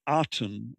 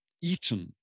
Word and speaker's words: Aten: but the Artem is actually spelt with the reed glyph, And the reed glyph is more of an E than it is an A. So it's not the Aten as we normally pronounce it. It's more the Eden.